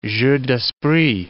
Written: jeux d'esprit

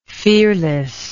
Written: fearlessness

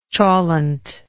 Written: cholent

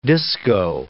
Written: disco

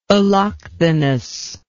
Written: allochthonous

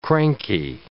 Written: cranky

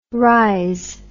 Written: rhiz

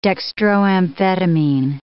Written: dextroamphetamine

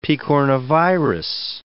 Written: picornavirus

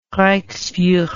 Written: reichsfuhrer

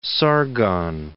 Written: Sargon II

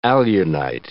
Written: alunite

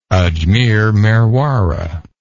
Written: Ajmer-Merwara

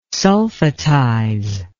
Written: sulfatize

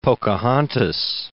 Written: Pocahontas

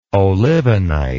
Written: olivenite

